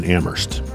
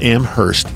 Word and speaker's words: Amherst: Friends don't let friends pronounce the H in Amherst. It's not Amherst, it's Amherst. You don't say Hingham, do you?